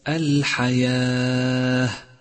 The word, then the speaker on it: الحياة: ثالثا لاحظ الواو الرمادية في كلمة الحياة، واعلم أنها لا تلفظ ولذلك هي رمادية في مصحف التجويد إشارة إلى عدم لفظها.